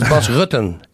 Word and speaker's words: Bas Rutten: This is Bas Rutten. It's not Rutten.